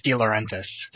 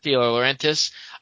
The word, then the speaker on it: De Laurentiis: I'm pretty sure it's De Laurentiis. – De Laurentiis.